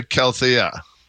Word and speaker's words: Calathea: Okay, that's the Calathea.